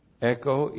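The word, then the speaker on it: echo (ἔχω): Echo, E-C-H, long O.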